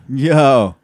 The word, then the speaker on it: Yeoh: Yeoh.